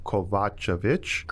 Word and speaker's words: Kovacevic: It's Croatian. It's Kovacevic.